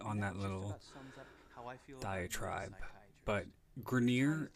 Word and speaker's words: Grenier: First of all, Grenier. Is not the way the name is pronounced. It's French. It's pronounced Grenier.